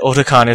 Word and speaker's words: Otakon: I say it Otakon, but she says it Otakon. – Yeah. – So I guess that means that Otakon is the right way to say it.